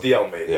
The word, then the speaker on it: De Almeida: It's De Almeida.